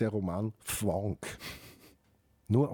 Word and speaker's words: Fvonk: Und die Sue Imhoff macht gemeinsam mit der Susanne Höllier eine Sendereihe, die heißt Fwonk. – Fwonk dich frei. – Dich frei. Und Fwonk schreibt man F-V-O-N-K.